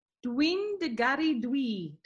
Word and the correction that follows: Dwi'n dy garu di: Did I say that right? – Dwi'n dy garu di. – Di. Dwi'n dy garu di.